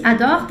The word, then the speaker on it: adorent: Le femme está no plural, então esse ador tem que ser ENT no final.